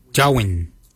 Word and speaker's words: djowiny: Kart is spelled G-A-A-D. Kart. The next word is hair. For hair, we say jawin. Jawin. Jawin is spelled D-J-O-W-I-N-Y.